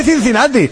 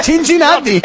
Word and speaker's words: Cincinnati: No se dice Cincinnati, se dice Cincinnati.